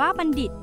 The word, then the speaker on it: บัณฑิต: บัณฑิตไม่อ่านว่าบัณฑิตล่ะครับทอมนโทออกเสียงได้สองเสียงจ้ะคำที่ออกเสียงเป็นททหารก็มีเช่นมนทามนโทมนทนและคำที่ออกเสียงเป็นดอเด็กก็มีเช่นบัณฑิตมนดบจ้ะ